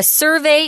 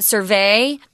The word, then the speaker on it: survey: It's not survey. That's a verb. Survey is the noun.